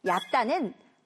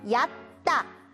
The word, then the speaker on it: 얕다: 강물에 물이 얇다. – 발음을 정확해 줘야 되는데요. 얇은 건 가는 걸 이야기해요. 우리가 지금 이야기하고 있는 깊다의 반대말은 얕다. 자, 선생님을 따라해 봅니다. 얕다.